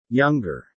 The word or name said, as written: younger